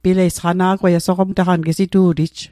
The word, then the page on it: sqana'gw: Mi'gmaq